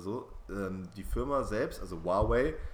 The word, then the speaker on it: Huawei: auf Deutsch ausgesprochen Huawei. Irgendwie so ein Abhörskandal gab. – Aber man spricht es wirklich so aus in deren Sprache, ja, genau. Huawei.